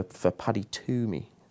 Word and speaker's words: Twomey: Could be life-changing, you never know, for Paddy Twomey. Is that right? – Indeed, yeah. – Yes, good. – Silent W.